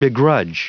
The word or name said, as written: begrudge